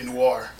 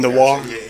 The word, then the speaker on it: noir: Yeah, noir. – Okay, noir.